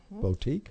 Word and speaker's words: boutique: And now there's a unique thrift boutique. – That's right. – Wow, I even said it right. – Boutique. – Wow. – You got it.